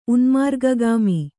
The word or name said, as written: ಉನ್ಮಾರ್ಗಗಾಮಿ